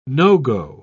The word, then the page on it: no-go: British English